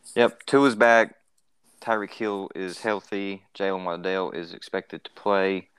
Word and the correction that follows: Waddle: I wish y'all would say his name is Waddle. That's why he does the penguin dance when he celebrates. He waddles like a penguin because his last name is Jalen Waddle.